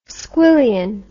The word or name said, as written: squillion